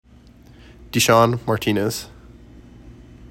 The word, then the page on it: D’Sean Martinez: dih SHAWN mar TEE nez